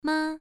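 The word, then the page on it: ma: tone 1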